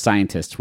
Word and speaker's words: scientist: Scientist isn't a typo. It's just scientist with a sh sound at the start.